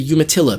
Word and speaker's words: Umatilla: the Umatilla people. Is that, am I pronouncing that correctly? – Yep. – And maybe... – Cayuse Umatilla Walla Walla.